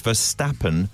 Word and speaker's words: Verstappen: For God's sakes, Baldy, it's Verstappen, not Verstappen.